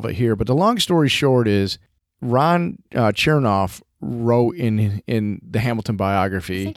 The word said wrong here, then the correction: Chernow: I think you said his name. – I always do. How do you think you say it? – It looks like it's spelled like Chernow. – You're right. It's Chernow.